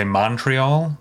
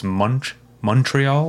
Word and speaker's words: Montreal: Americans have a tendency to say Montreal, but it's Montreal, like mm-hmm. Munch. Mm-hmm.